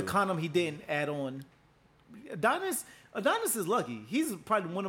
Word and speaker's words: Adonis: Like Lil Adonis, how you say his name? – I think no, it's Adonis. His name's Adonis.